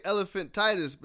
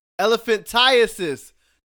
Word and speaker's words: elephantiasis: Most people say elephantitis, but that's not how you're supposed to say it.